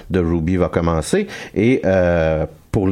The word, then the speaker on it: RWBY: Ça s'écrit R-W-B-Y, mais ça se prononce Ruby,